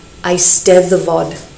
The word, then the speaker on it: eisteddfod: The final syllable is pronounced to rhyme with I. Those two D's in the middle are a TH sound like the, eisteddfod.